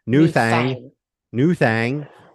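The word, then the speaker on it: Nu Thang: And New Thing was released on... – You said it wrong. You can't say it that way. – Yeah. Sorry. New Thang was released in September 1990.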